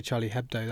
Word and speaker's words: Charlie Hebdo: Just say Charlie Hebdo, that's fine. – Charlie Hebdo.